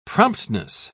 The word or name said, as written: promptness